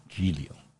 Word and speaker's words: Giulio: Giulio in Italian is pronounced with a soft G. Giulio.